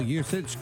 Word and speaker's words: Juszczyk: Remember a week or so ago, Kyle Juszczyk got fined a weird number.